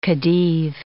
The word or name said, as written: khedive